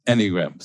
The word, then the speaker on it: Enneagram: Enneagram. So accent on the first syllable.